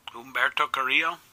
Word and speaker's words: Humberto Carrillo: Humberto Carrillo? – Yeah.